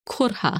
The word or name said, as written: Kurhah